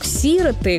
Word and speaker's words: сироты: Правильный вариант только такой – «сироты». Ударение на первый слог «сироты», которое порой встречается не только в разговорной речи, но и в официальных выступлениях, звучит на радио или в телевизионных ток-шоу неверное.